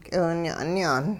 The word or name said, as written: k'iwinya'nyaan